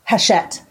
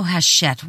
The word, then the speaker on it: Hachette: by Hatchett. I said it right, didn't I? – Hachette. – Oh, Hachette. Whatever. – Hachette. – Whatever. – It's a Hachette.